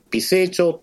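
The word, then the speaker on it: 美星町: 茨市の中に美しい星の町と書いて、で、美成町って呼ぶ町があるんですけども。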